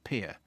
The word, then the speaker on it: Pierre: Incidentally, Pierre, South Dakota is pronounced Pierre. So it's written as in the French for Peter, but it's pronounced P-I-E-R, as in a boat dock.